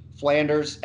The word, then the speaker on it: Flanders: It's M-I-K-E-L. Flanders, F like Frank, L-A-N-D-E-R-S like the Simpsons.